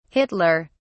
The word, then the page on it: Hitler: British English